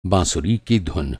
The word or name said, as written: धुन